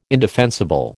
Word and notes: indefensible: American English

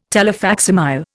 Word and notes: telefacsimile: Indian

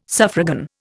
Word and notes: suffragan: Indian

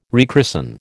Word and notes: rechristen: American English

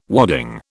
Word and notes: wadding: British English

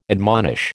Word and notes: admonish: American English

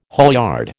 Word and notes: haulyard: American English

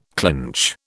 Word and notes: clinch: British English

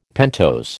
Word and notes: pentose: American English

